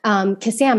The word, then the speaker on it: Kassam: And I said, "Oh, Kasam." And he's like, "Isn't it Kasim?" And I was like, "Oh my goodness, I have to," this is hysterical. Like, this person knows how to pronounce my name better than I do.